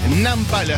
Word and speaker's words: Nampal: Nampal, así como suena, se escribe.